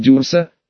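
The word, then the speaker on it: Дюрсо: Только правильно говорить Дюрса, потверже. Дюрса», — повторил парень.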